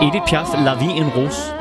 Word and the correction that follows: La Vie en Rose: Som jeg overhovedet ikke udtalte rigtigt. – Nej. – Hvordan skal det siges? – La Vie en Rose.